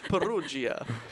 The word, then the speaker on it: Perugia: Say it with me, Matt. – Vincenzo Peruzzi. – You are so bad. Perugia. – I think they pronounce their G's as S's.